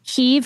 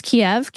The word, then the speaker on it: Kyiv: Kyiv versus Kiev. Kiev is a Russian version of Kyiv. – Mm-hmm. Mm, right, yep. Exactly. Yep. One syllable versus two.